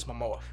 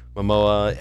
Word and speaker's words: Momoa: It stars Jason Momoa. Am I saying that right? – Yes, Momoa. – Momoa as the title character with Amber Heard, Willem Dafoe.